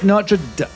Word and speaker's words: Notre Dame: It's Notre Dame.